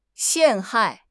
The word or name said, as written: 陷害